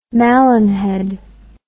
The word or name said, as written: malinhead